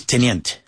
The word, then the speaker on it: teniente: It's teniente.